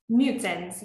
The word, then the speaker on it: Knudsen: And I would love to hear, like, so do you have the scoop on why the founders, which I believe the last name is pronounced Knudsen's, is that correct? – Knudsen's, yeah. – Knudsen's, I was close.